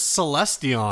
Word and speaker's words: Celestion: How would you pronounce it? – I'm pretty sure it's Celestion. – Celestion G12H greenback UK speaker.